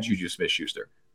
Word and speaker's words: Juju Smith-Schuster: they had Juju Smith-Schuster.